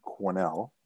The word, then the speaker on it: Quesnel: I want to know what minor hockey and growing up in Quesnel is like. – Well, first of all, it's pronounced Quesnel. It's a Quesnel, so it's French.